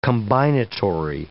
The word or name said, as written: combinatory